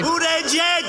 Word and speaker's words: Buttigieg: Buttigieg! – Buttigieg.